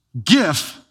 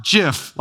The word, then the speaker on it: GIF: It's GIF, hard G, not JIF, like the peanut butter.